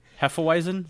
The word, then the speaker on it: Hefeweizen: Hefeweizen? Is that how you say that? I sold beer for 7 years and I don't know how to say that. So, Hefeweizen? – I think that's how you say it. – Hefeweizen? – Yeah. Yeah, I think.